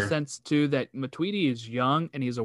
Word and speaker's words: Matuidi: Because if this happens to Matuidi, is that how you say it? – Matuidi. – Matuidi.